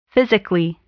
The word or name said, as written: physically